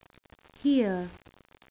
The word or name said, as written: here